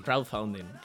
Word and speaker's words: crowdfunding: Crowdfunding.